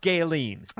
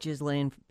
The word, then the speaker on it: Ghislaine: It's pronounced Gaylene. – I really like Ghislaine better.